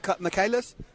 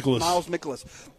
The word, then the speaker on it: Mikolas: Sanchez versus, how do you say this guy's name, Michaelis? – Miles Mikolas.